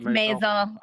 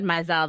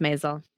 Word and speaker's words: Maisel: It's Maisel. Last time we talked about it, I said Maisel the whole time, but it's Maisel.